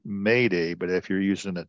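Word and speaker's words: m'aidez: It's like M apostrophe AIDEZ Maydez. – Yeah, them French people spell things funny. – But it's pronounced Mayday,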